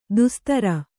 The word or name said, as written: ದೋಸ್ತಾರ